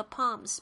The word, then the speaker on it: palms: 这个 palms P A L M，但是这个 L 没有发音呢。